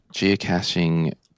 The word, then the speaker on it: geocaching: Geocaching pronunciation. Here we go. Let's have a listen here. Oh, no. This is interesting. I'm going to play it here. – Geocaching. – Oh, it is caching.